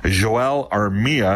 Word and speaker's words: Joel Armia: Joel Armia, and that's the pronunciation they're going with, Joel, he is out with an upper body issue.